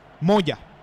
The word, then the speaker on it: Moya: ¿Es Moya o es Moya? – Moya. – Ah, ¿no tiene acento en la A? – No tiene acento en la A.